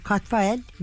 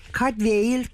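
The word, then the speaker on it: Cadfael: Her şeyden önce Katfael Galli, yani İngiliz değil, asıl olarak Galli ve Cadwail, Cadwail, Cadwail gibi şekillerden, ben hani her seferinde yanlış bir şey söylemeyelim, gördüğümüzü okuyalım diye Katfael'i tercih ettim.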